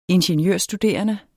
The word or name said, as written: ingeniørstuderende